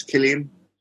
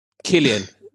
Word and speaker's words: Cillian: I actually think it's Killian. – Killian. You're right.